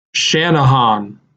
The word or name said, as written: Channahon